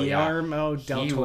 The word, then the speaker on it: Guillermo del Toro: Guillermo del Toro. – Guillermo del Toro.